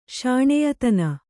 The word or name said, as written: ಶಾಣ್ಯಾತನ